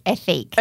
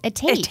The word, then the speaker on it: Ethique: Because I thought it was Ethique. – Okay, and I thought it was... – Ethique. Ethique. So it's the brand you would have seen, E-T-H-I-Q-U-E, but it's pronounced Etique.